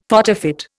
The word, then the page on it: photofit: Indian